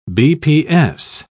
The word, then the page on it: bps: British English